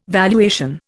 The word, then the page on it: valuation: Indian